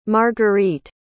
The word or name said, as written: margurite